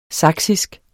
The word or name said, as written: saksisk